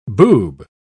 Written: boob

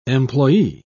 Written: employee

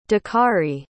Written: dakarai

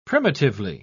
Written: primitively

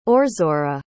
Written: orzora

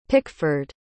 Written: picford

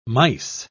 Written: mice

